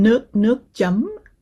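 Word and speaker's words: nước chấm: So actually, you mentioned, how do you pronounce it? Nuoc chum? – Nuoc chum. – Nuoc chum. Okay. – Yeah, there you go.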